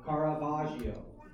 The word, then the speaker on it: Caravaggio: Caravaggio.